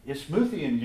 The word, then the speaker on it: Isthmian: Isthmian Games. Isthmus is what it's based on.